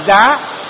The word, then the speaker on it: giá: Chứ gia còn đọc là giá.